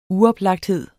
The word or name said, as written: uoplagthed